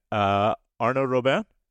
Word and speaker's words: Arnaud Robin: Welcome and bienvenue, Arnaud Robin. – C'est un plaisir. My pleasure to be with you. – Did I say your name? Let me try that again. Arnaud Robin? – Arnaud Robin.